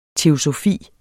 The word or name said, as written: teosofi